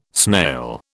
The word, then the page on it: snail: British English